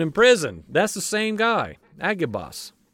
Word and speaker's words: Agabus: We've heard him as Agabus. But I would like to say his name for how it was, best I can tell, pronounced then. It's Agabus.